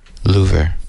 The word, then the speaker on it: Louvre: I think it's in the Louvre. Is that, did I say that right? – Or French pronunciation, Louvre.